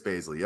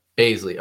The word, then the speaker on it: Bazley: Did I say that right? – Darius Bazley, yep. – Baisley. Okay. Yeah.